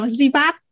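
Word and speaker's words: Sebald: À ví dụ như bác Đăng Thư dịch rất nhiều quyền của hôm trước mình mới đọc mình phát hiện ra là mình phát âm tên nhà văn này sai. Dạ, mình phải đọc Zibat đúng rồi.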